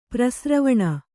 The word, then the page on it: ಪರಿಸ್ರವಣ: pari sravaṇa